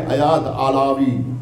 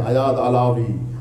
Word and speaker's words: ایاد علاوی: اب آپ پڑھیے ایاد علاوی, یہ امریکیوں کا تلفظ ہے آیاد علاوی،